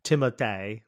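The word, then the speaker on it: Timothée: I mean, actually he says, I think on the interview, that's how you say his name, is Timothy, like that. But not, I mean, we're not saying it with an accent. I think we just with a French accent.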